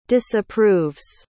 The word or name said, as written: disapproves